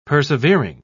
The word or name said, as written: persevering